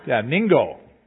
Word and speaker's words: niangao: It's Nianago. How do you say that? Does anybody can? N-I-A-N-G-A-O. Is that? Yeah, what they said. Yeah, Ningo. Oh, Ningo. Okay.